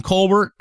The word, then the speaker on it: Colbert: And Stephen Colbert is great. Do you know Colbert is not how we pronounce, that's not actually how his last name is pronounced.